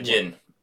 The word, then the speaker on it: Jinn: The mother fills in some backstory and basically saying she's a Dinj. – Yeah. – Which is a Jinn. – A Jinn, sorry. Yeah. silent D, silent D at the beginning of it, just like Django.